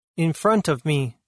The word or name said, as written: In front of me